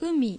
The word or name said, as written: Umi